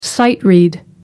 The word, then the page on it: sight-read: American English